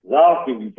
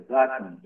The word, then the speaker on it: Tzolkin: And 20 refers to the 20 solar eclipses as archetypes on the Zolkin calendar. That's spelled T-Z-O-L-K-I-N. Calendar. Yes. The Zolkin calendar.